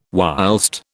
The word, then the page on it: whilst: British English